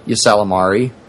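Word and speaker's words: Ysalamiri: I always pronounce it like Yesalamari. – Yesalamari. That sounds more accurate. Yeah, I'm looking at the spelling. That looks correct. I always used to say Yesalami.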